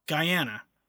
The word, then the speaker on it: Guyana: It is the national animal of, I always say this country wrong. Guyana. That's how you say it. That's right.